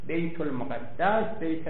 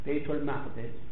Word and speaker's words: بیت المقدس: ولی مجمع البحرین میگه بیت المقدس دو جور تلفظ میشه، هم با تشدید هم بدون تشدید. الفلام نیاورده سر بیت، بیت المقدس، بیت المقدس، میگه هر دو قندش.